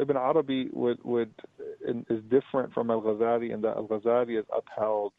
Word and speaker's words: Al-Ghazali: I may probably pronouncing it wrong. Is it Al-Ghazali? – Al-Ghazali the foundation. – Yes.